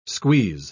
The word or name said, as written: squeeze